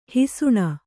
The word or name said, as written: ಹಿಸುಣ